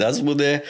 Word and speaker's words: Azmoudeh: Also ich heiße Said Asmude, bin äh, jetzt 49 Jahre alt.